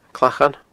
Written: Clachan

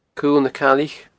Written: Cuil na Caillich

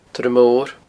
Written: torr mor

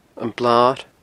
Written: Blar

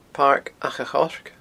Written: paircachachoirce

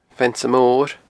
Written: Feannsa Mor

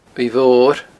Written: uaimh mhor

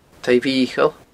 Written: taigh mhicheil

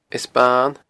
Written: Eas Ban

